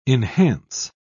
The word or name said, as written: enhance